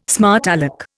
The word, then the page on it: smart alec: Indian